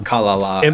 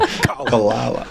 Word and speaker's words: Kalala: I'm not really sure like what the inflection on that last syllable is supposed to be, but it's Kalala, actually. – Emphasis on the first syllable. – Kalala.